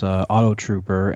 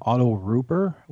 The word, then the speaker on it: Auto Rooper: So this was a set, and it's, like I said, it's the girl's name is Atari, and it's Auto Trooper, and it's actually, for some reason, they pronounce it Auto Rooper, they drop the T, I don't know why, but it's Auto, R-O-O-P-E-R, Auto Rooper.